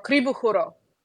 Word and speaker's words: krivo horo: The last movement is called Festival, and that's very directly based on a Bulgarian dance called a crooked dance or krivo horo. How do you say it? – Horo, krivo horo. – That's much better. That's much better than I.